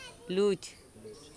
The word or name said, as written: luc